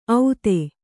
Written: ಔತೆ

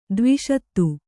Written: ಧ್ವಸ್ತ

